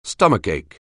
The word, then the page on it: stomachache: anglais